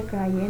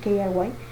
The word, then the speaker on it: KRY: que no se pronuncia Cry, nada más que nosotros decimos así, KRY, mínimo dame a Show Me, tráeme a Shomi.